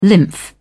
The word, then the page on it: lymph: British English